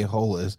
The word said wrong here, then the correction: Holthus: Holthus, excuse me if I didn't say that right.